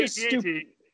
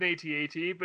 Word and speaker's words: AT-AT: I still call it an AT-AT, but now every time I say it, I feel like I'm wrong. Jordan can have AT-AT. – Anyways. – The official pronunciation rhymes with the phrase hat-hat. – There you go. – An H-A-T? – Yes.